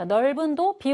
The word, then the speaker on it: 넓은: 자, 넓은도 비읍 올리면 됩니다. 넓은.